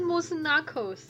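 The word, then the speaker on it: Moose Knuckles: 应该是 Mus Knuckles。 – 哦，Knuckles。 – 因为它的 K 应该是不发音，它前面是 K。 – 哦，第一个字是 K，第二个字 N 哦。 – 对啊。 – 哦，前面不发音哦。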